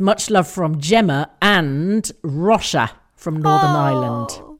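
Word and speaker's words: Róise: No, she says it's pronounced Rosha as in John.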